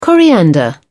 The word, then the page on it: coriander: British English